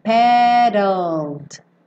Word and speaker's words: paddled: E como o E não vai ser pronunciado, pensa em sair do D e já ir pro L no céu da boca. Então fica paddled, paddled, tá?